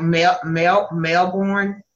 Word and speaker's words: Melbourne: Sounds like Melbourne. Did I say it correctly? Yeah. It's a lot like Louisiana, because we can go through several seasons in a day or a week's time.